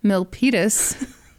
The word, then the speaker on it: Milpitas: Milpitas. Hey, not bad. – Yeah. – Mm-hmm. – You killed it. – Yeah. Boom. So he ordered Brooke to drive to Milpitas.